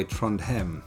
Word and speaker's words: Trondheim: We will have preview and reaction shows and actually be at the final on the 3rd of February in Trondheim, or I think as they say in Norway, Trondheim.